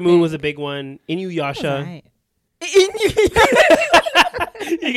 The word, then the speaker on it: Inuyasha: Inuyasha.